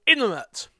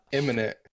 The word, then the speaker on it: imminent: I said that wrong. Word wrong. Jesus. – Imminent. – I was letting you say it wrong now. Imminent. – Imminent. – Am I saying it wrong? Imminent. – Imminent. – Yeah, there you go.